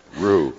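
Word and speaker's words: Roux: The X is silent. Rue.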